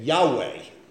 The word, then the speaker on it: Yahweh: But if you took those vowel signs out, you could make J-H-V-H anything you want. Well, how do the Germans pronounce a J? Yah. Yeah. Okay, how do they pronounce a V? Wah. Okay, so they made it Yahweh, which was a Canaanite god.